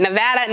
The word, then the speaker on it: Nevada: Nevada, Nevada, Nevada.